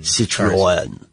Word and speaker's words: Citroën: Citroën.